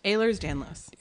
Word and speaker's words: Ehlers-Danlos: Ehlers-Danlos. – Ehlers-Danlos.